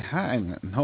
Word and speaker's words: Heinlein: Heinlein, I believe is the way you pronounce that. – Robert A. Heinlein.